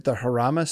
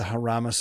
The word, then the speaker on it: Jarama: Yeah, so they've had like really terrible floods in Valencia, and Formula E preseason testing was moved to the, I believe it's pronounced the Jarama circuit, which is outside of Madrid.